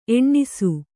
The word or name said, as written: ಎಣ್ಣಿಸು